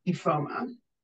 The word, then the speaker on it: Ifeoma: Um, so first of all, it's pronounced Ifeoma. Oh, it is. Yeah. And um, which I've had like I don't know like five people over the course of my lifetime ever get that right the first time.